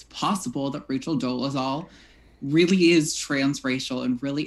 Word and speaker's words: Dolezal: Dolezal, let me know if I pronounced it wrong. – Dolezal.